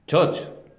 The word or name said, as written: txotx